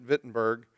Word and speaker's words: Wittenberg: And it is the day where Martin Luther went to the church at Wittenberg, okay, spelled with a W, but they use a V instead, okay, or they pronounce it with a V.